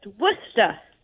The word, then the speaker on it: Worcestershire: Did I say it right? – Almost. Worcester. – Worcestershire. – Yeah. Worcestershire. – No, you're wrong. Worcestershire. – You don't have to say shire. You just say Worcestershire.